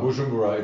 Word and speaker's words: Bujumbura: And recently we were emailed because there were three brothers in, I don't want to get this wrong, Bujumbura, Burundi. Africa. Is that how you say it? – Yeah, what? Bujumbura, it's in Burundi.